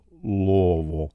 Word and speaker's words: Louisville: It is Louisville, and the way you say it, if you're from Louisville, is to collapse it all into one melted syllable, Louisville.